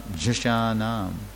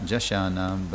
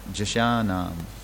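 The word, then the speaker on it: jhaṣāṇām: Everybody's saying with the wrong accent and the wrong syllable, but it's, you got to say long A, or first short A, and then long, and then too long. So it's Jashanam. Not Jashanam, but Jashanam.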